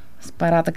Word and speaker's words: Спарата: Спарата, както правилно ме поправя Ива, когато объркам ударението, край село Селянин, Вакарело.